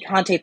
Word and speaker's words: Conte: I don't know if I said his name right. – I know who you mean. – Yes. – There's Bobby Conte in there. – Bobby Conte Thornton. That's how you say it. Oh, yeah. Bobby Conte Thornton. That's how you say his name.